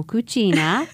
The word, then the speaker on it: cucina: So cucina is the Italian pronunciation. In Spanish, you will say cocina, and it means kitchen in both languages.